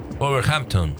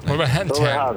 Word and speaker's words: Wolverhampton: Wolverhampton. – Wolverhampton.